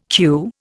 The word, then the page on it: queue: Indian